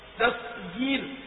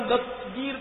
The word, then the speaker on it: دستگیر: اور غوث اعظم دستگیر پر میں عرض کر دوں کہ عام طور پہ ہم لوگ کہتے دستگیر, دستگیر یہ تلفظ غلط ہے, یہ تو دس الگ ہو گیا, تغیر الگ ہو گیا. اصل میں یہ دو الفاظ کا مرکب ہے اور دونوں فارسی الفاظ ہیں, دست ہاتھ اور گیر مانے پکڑنا.